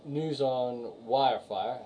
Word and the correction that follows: Wi-Fi: I said it right for once. On Nintendo. – Wi-Fi. – Said it wrong.